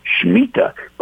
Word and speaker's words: Shemitah: And that word remission is the word Shemitah. By the way, that's spelled S-H-E-M-I-T-A-H, Shemitah.